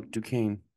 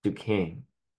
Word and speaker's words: Duquesne: Oh, Duquesne. – Duquesne.